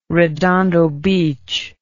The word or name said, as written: redondo beach